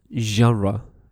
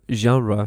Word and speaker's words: genre: You know, now I would like to speak about something which is, you know, very, very easy, and it's the term genre. It's very easy, but sometimes hard to pronounce for, you know, some students. So it's G-E-N-R-E-S, genre. It comes from French, and it means género, género musical.